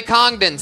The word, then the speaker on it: Congdon: Kimberly Congdon, spelled like it sounds. C-O-N-G-D-O-N.